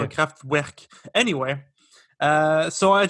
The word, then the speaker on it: Kraftwerk: He's one of the two founding members of a band, a very iconic band called Kraftwerk. How is that pronounced? – Beautiful. – Thank you so much. Because, you know, for a while I thought it was pronounced Kraftwerk, you know? – Or like a UE or something?